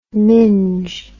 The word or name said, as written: minge